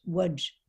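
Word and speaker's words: Łódź: I think it's pronounced Wudge.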